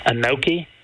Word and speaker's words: gnocchi: Gnocchi. – That is correct. There's your silent G at the beginning of that one. G-N-O-C-C-H-I.